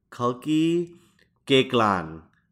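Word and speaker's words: Kalki Koechlin: her name is pronounced Kalki Geklan. That's how she pronounced it.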